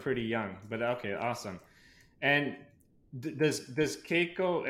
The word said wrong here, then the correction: Kaiko: Am I saying it right? Keiko, right? – Kaiko. – Kaiko.